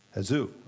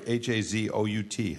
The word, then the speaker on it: Hazout: It's pronounced Hazout. I'm sorry. H A Z O U T. Hazout.